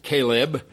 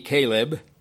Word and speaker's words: kheleb: It's the same Hebrew word, Caleb. Almost sounds like the word Caleb as the man Caleb, K-H-E-L-E-B, Caleb.